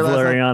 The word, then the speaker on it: Valeriano: How do you say your Valeriano.